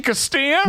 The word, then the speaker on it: Castilla: It's actually Castilla. – No, because it is. That's just the way it's called. – It's called Castilla.